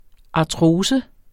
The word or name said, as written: artrose